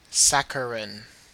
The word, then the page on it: saccharine: Australian English